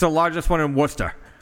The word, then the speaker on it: Worcester: Yeah, Worcester. – That's how you say that.